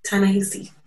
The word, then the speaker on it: Ta-Nehisi: Ta-Nehisi.